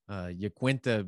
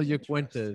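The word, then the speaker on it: Iaquinta: UFC 223 had Yaquinta, because that is how you pronounce the name. So start spreading the news and telling your friends. Al Yaquinta is how you pronounce it.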